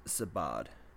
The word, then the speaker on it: Sadbh: Finn's first wife and most notable was Sabaad, and it's spelled S-A-D-B-H,